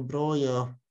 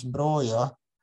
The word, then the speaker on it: Broja: Jag, jag har funderat lite på det här och jag är rätt säker på att vår albanske strikers namn uttalas Broja. Han är född i Slau till albanska föräldrar och har inga spanska rötter vad jag vet. Hade han haft spanska rötter så hade det varit Broja, men jag tror att det albanska uttalet är något i stil med broja.